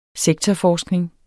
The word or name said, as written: sektorforskning